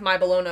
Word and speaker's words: My Bologna: On IMDb, it said, just before Joyce Byers arrives at Mr. Clark's house, the song it's My Baloney, but it's pronounced My Bologna when he's singing it.